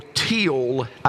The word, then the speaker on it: Thiele: The one who has deciphered biblical chronology that I follow most closely, Edwin Thiele, or Teal, I think he pronounced it, gives the dates of Jehu from 841 to 814 BC.